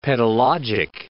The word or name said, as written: pedologic